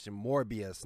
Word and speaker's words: Morbius: Did I say Mobius? Oh, my apologies, folks. Mobius is the planet, is the home planet to Sonic and Friends in the Sonic Archie comics. Yeah, that's a big mispronunciation. Morbius, not Mobius.